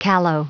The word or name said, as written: callow